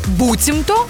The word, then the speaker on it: буцімто: Пам'ятаємо, пишеться разом і наголос завжди на першому складі. Буцімто.